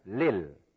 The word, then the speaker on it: لِلْ: للمتقين لي for L the لل لي L لل we pronounce li and al together, lil.